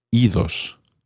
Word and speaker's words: idos: So when ir becomes reflexive, irse, meaning to go away, you actually do pronounce the D. Idos, go away. This is probably because ios is quite tricky to say. Idos sounds better.